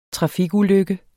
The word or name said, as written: trafikulykke